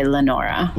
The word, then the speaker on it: Leonora: It looks like it says Leonora, but she went by Lenora.